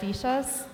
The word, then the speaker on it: villa: And then kind of, you wouldn't see these unless you're looking for them, but there's these slum villages called villas. They pronounce the double L as a sh sound.